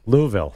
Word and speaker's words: Louisville: I love the way you actually pronounce it the right way. Louisville.